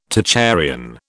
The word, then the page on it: Tocharian: British English